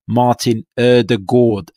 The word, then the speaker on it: Ødegaard: We'll be releasing a Jonas Jever interview, which is a bit of a deep dive into Arsenal's new signing, which is pronounced Martin Ødegaard. That's how you're supposed to say it,